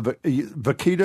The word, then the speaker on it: vaquita: It's called Entangled, and it's almost the exact same story about the vaquita, is how you say the porpoise, Si? – Yeah, yeah.